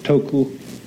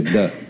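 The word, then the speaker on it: de (德): The Japanese pronounce it toku, the Cantonese duck, and the northern Mandarins approximately de.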